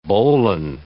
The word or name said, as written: bowline